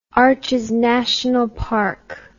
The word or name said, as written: Arches National Park